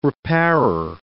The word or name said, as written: repairer